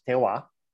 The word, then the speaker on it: terroir: Terroir, also spelled T-R-T-E-R-R-I-O-R, but pronounced terroir.